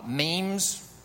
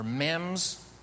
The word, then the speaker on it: meme: Memes, or memes, M-E-M-E, and let's just say meme. Can we all say meme?